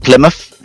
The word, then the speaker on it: Plymouth: It's in an area called Plymouth, hence the muff, not mouth. Most people say mouth, but it's Plymouth. So we pronounce it.